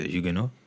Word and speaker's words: Huguenots: And as about the Huguenots, you know, the Huguenots? There's a French in South Africa, you know? – Huguenots. – Yes, some people say it like this.